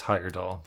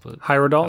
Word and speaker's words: Heyerdahl: Christopher Heyerdahl? Is that how you pronounce that? – I think it's Heyerdahl. – Heyerdahl, excuse me.